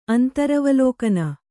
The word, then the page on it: ಅಂತರವಲೋಕನ: kannada